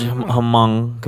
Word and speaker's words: Hmong: There's a large Hmong community, mm-hmm. H-M-O-N-G.